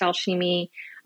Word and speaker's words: Alchimie: I think I missed that lecture or that class because I picked Alchemy Forever, and Alchemy is spelled A-L-C-H-I-M-I-E. It is pronounced Alchemy. A lot of people pronounce it alchemy. They're not wrong.